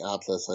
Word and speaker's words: Atlus: Altus, I should say. Can't even pronounce the creators right. But is this a huge announcement, or am I overplaying it there? – Firstly, it is actually Atlus.